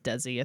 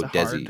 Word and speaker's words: Desi: Uh, uh, just for reference, I believe it's pronounced Desi. I think. – oh, Desi.